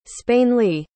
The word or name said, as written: spainleigh